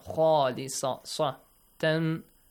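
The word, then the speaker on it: خالصة: Make sure it's a heavy sound. Khalisatan.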